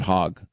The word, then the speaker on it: Hogg: Remember this guy, David, is it Hogg or Hogue? I'm not trying to purposely mispronounce it. I think it's David Hogg.